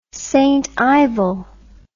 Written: stivel

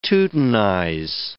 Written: teutonize

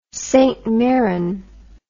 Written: St Mirren

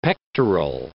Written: pectoral

